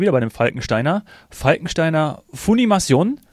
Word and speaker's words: Funimation: also es ist wahrscheinlich eher Fun und Animation, das glaube ich auch. – Ja, Funimation.